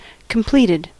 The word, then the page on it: completed: American English